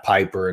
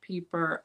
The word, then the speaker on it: Pieper: So I'm just going to alternate it like half Piper and half Pieper. – Well, if it's German, then the chances are that it would be pronounced Pieper. I is most often pronounced as E in the German language.